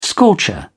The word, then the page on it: scorcher: British English